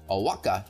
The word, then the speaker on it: Oaxaca: A project coordinator brought 3-foot-tall statues of frogs, lobsters, and iguanas from Oaxaca to the restaurant.